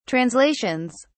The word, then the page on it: translations: British English